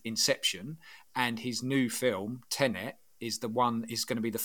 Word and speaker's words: Tenet: I think it's pronounced Tenet. It's T-E-N-E-T.